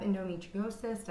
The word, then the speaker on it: endometriosis: you know, if you have endometriosis, did I say that right? – Yeah.